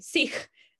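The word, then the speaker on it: seekh: And like, he used to pronounce it sheesh. And I was like, no, lame, Sikh.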